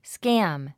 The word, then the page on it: scam: skǽm; スキャム